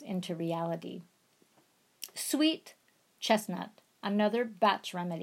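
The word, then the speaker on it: Bach: Clematis, a batch remedy, this is pronounced batch, is effective for those of us who are asleep in dreamland.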